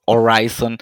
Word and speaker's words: Horizon: La H es muda, sí. Horizon.